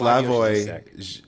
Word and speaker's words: Slavoj: Old how do you pronounce, I always say Slavoj. – It's Slavoj. I think it's Slavoj.